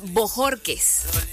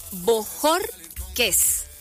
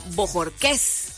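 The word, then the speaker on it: Bojórquez: Mi apellido es Bojorques. Bojorques. Entonces tiene como que el acento en el jor. Pero hay otras personas que me dicen Bojorques.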